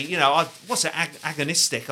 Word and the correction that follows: agnostic: I'd say. Is that how it's pronounced? – Agnostic. – That's it, absolutely.